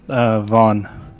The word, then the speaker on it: Von: My name's Vaughn. – Oh, Vaughn. – V-O-N. – V-O-N. Oh, normally you spell it V-A-U-G-H-N, but V-O-N, that's interesting.